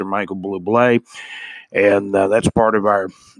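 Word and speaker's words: Bublé: Michael Blue Blay, it's pronounced Blue Blay.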